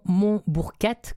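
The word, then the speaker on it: Monbourquette: Oui, donc il y a eu la Maison Beaubourquette qui a fermé en 2018, malheureusement. Mais si on va quand même encore sur le site de la Maison Montbourquette, comme ça se prononce, oui.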